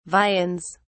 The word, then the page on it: viands: British English